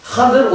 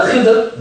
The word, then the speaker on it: Khidr: Schaut, was Khadr oder Khidr, beides ist richtig. Die Gelehrten haben einen Meinungsunterschied, wie er richtig ausgesprochen wird.